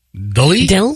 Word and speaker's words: Delee: Miranda Deely, her middle name is D-E-L-E-E. How the hell do you pronounce that? – Deely? – Deely. – Okay. – It's D-E-L-E-E. – Okay. – Deely. Did you say two E's? – No. Two E's at the end.